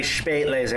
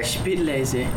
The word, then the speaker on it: Spätlese: Don't you pronounce it with an E? – You're right, that'd be Spätlese. – Spätlese.